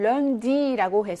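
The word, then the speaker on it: lundi: 1번에는 런디라고 해서 이거는 ü 발음, ü 다음에 n이 나와 있기 때문에 이거는 비모음이 되는 거죠.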